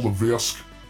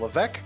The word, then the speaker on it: Levesque: Best wishes to Paul Levesque. Triple H. – I believe it's pronounced Levesque.